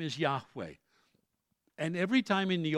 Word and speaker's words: Yahweh: Lord there translates the Hebrew four letters, YHWH, pronounced Yahweh. God's name is not Jehovah. They don't have a J or a V in their alphabet. The Jews could not have physically said the word Jehovah.